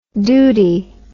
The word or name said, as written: doody